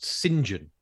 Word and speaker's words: Saint John: But the, the hang on, the English name, which is written Saint John, is pronounced Sinjin.